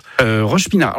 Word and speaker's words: Roche-Pinard: Vous changez de quartier, vous avez à Roche-Pinard. Alors, c'est ça, hein, c'est bien comme ça qu'on prononce. – Roche-Pinard. Alors, je suis dans le coin de Roche-Pinard et après, je change de quartier, je vais du côté de Gramont, en fait.